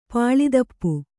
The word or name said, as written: ಪಾಳಿದಪ್ಪು